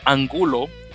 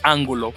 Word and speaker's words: Angulo: Y finalmente, obviamente, Cafeteros Pro, tienen un caballero que de hecho no lo conocía anteriormente, Carlos, lo pronunciaron como Angulo, pero como eso suena tan tonto, voy a decir Carlos Ángulo, porque me gusta más cómo suena eso.